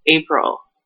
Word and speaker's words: Apryll: Double L April.